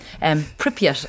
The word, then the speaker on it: Pripyat: Pripyat, am I pronouncing that correctly? – Good for me. – Yeah, that'll do.